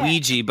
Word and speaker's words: Ouija: Ouija, but I think it's supposed to be we and ya or ja. So it is pronounced, if you're pronouncing correctly, Ouija, you know, on a Ouija board.